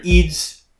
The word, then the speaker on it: Eid: And by the way, I sound like I'm being an a-hole when I say Eid. That is actually how I would pronounce it if I spoke Arabic. Although, there is a glottal stop in Eid, but I can't really mimic that because I'm not Arabic.